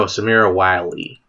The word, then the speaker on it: Samira Wiley: Samira Wiley. – Samira Wilde. Who's that? – No, Samira Wiley. – Wireless. How do you spell that? – W-I-L-E. No, you want Samira. S-A-M-I-R-A.